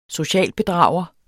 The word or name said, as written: socialbedrager